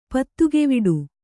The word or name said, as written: ಪತ್ತುಗೆವಿಡು